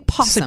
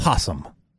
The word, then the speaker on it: opossum: You're not pronouncing the O. You're saying possum. – It's possum.